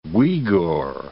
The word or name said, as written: Uigur